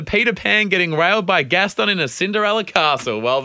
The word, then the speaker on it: Gaston: Gaston. – Gaston.